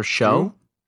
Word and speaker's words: Shough: Tyler Shough.